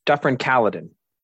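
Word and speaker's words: Dufferin–Caledon: Yes, Dufferin Caledon.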